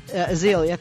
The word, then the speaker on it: Azealia: And Azalea Banks, is that how you say the first name? Is a rapper. Chingy, Azealia, thank you.